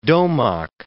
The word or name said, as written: Domagk